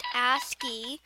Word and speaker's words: ASCII: ASCII, pronounced ASCII, is the abbreviation for American Standard Code for Information Interchange.